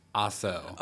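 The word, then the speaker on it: Pikasso: Yeah, but they spell it with a K. Oh, really? Yeah. – Oh, so it's like weird. – Picasso. – Oh, like a pick, like uh-huh. a guitar pick.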